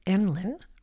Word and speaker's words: NLEN: NLEN, pronounced Enlin, rhyming with gremlin, is an acronym for near life experience narrative.